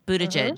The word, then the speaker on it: Buttigieg: So, for folks who are trying to figure out how to say it, like boot edge edge, right? Buttigieg, mm-hmm.